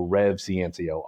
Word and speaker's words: Rev Ciancio: So Rev Ciancio, R-E-V-C-I-A-N-C-I-O.